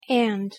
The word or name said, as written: and